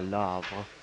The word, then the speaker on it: Le Havre: You don't pronounce the H, right? – Yeah, Le Havre.